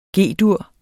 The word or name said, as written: G-dur